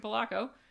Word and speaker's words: Polacco: Her name is Patricia Polacco.